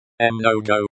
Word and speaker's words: много: Pronunciation. Mnogo, like the English word, mango.